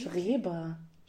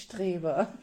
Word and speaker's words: Schreber: Schreber. Schreber. Nicht Streber.